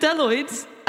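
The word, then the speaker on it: bdelloid: They also, they're called Deloids, and they begin with, guys, what do they begin with? What D? – Letter? – D. – A B. A silent B.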